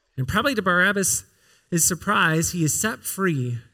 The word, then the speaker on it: Barabbas: In fact, pronouncing his name Bar Abbas highlights the name's meaning.